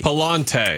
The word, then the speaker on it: Pallante: How do you say, is it Pallant or Pallante? – Pallante. – Pallante. That's Pallante. That's what I thought it was.